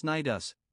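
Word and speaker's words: Cnidus: It was with difficulty that the crew brought the ship over against the harbor of Gnidus, pronounced Gnidus, a port on the extreme southwest corner of Asia Minor.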